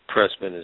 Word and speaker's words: Pressman: Mauri is spelled M-A-U-R-I-E. Pressman is P-R-E-S-S-M-A-N, one word.